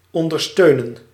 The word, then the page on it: abet: /ə.ˈbɛt/